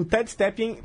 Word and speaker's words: Ted Stepien: Nesse contexto que o Victor está falando, tem um dono de, de time que ele precisa ser citado, que é o Ted Stepien, né? não sei se estou falando certo esse nome. – Ted, Ted Stepien. Stepien. Perfeito. Ted Stepien.